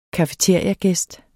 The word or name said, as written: cafeteriagæst